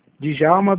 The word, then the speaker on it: Djalma: Djalma Brilhante da Costa. – O D é mudo, né? – Isso.